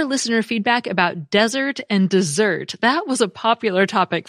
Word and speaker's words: Mount Desert: And indeed, the Mount Desert website says the name is pronounced dessert, quote, since it is of French origin, unquote.